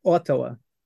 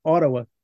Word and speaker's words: Ottawa: Os canadenses, porém, eles não pronunciam o T de certas palavras. Fica meio que um D meio mastigado, meio dentro da boca, assim, né? Ottawa, Ottawa, Ottawa.